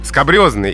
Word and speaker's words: скабрёзный: Скабрёзный, именно так надо говорить это слово. Произношение обусловлено происхождением, как это часто бывает. Слово пришло к нам из французского языка, а там оно восходит к латинскому «скабросус» — «шершавый, грубый».